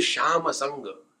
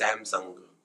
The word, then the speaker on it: Samsung: Gujaratis cannot pronounce sure. This is sir. So it is actually Shamasang. It is Samsung.